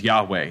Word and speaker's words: Yahweh: That's not really right. There's no J sound in Hebrew. In the Middle English, J made the Y sound, and so Yehovah became Jehovah, the way that we spell it. But he gives him this name, which is modern scholars give the vowels as Yahweh instead.